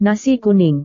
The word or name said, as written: nasi kuning